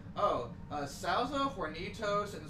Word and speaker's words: Hornitos: Hornitos, yeah.